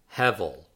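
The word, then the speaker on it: hevel (הבל): And this is pronounced hevel, hevel, hevel.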